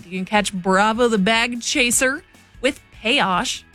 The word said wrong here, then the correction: Peso: I'm so sorry. I mispronounced that. Bravo the Bag Chaser with Peso. That show going down Saturday, September 19th.